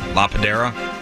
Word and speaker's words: La Padera: And he was last seen yesterday evening around 6:45 in the 12,000 block of La Patera. Am I saying that right? P-A-D-E-R-A. – That's La Padera? – La Padera.